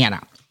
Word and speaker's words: Anna: I'm sure she says Anna because she's Russian originally. Anna would be the Midwest. The Midwest pronunciation would be Anna. Two hard A's.